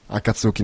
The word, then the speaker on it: Akatsuki: Akatsuki, no, è sulla prima sillaba.